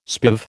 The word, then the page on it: spiv: American English